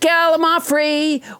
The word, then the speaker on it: Gallimaufry: Let's never forget Gallimaufry, which I pronounced wrong until nobody James Hyder corrected me.